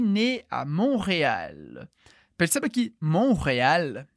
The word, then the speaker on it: Montréal: Como que a gente diz Montreal em francês? Montréal. Montréal. Então, em francês, a gente também escreve o T, mas a gente não pronuncia.